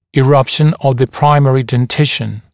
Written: eruption of the primary dentition

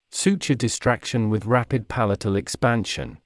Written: suture distraction with rapid palatal expansion

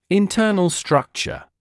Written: internal structure

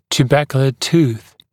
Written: tuberculate tooth

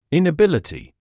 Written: inability

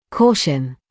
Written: caution

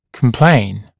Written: complain

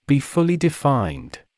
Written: be fully defined

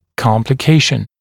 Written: complication

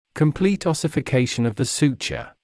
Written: complete ossification of the suture

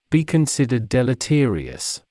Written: be considered deleterious

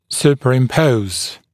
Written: superimpose

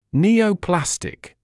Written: neoplastic

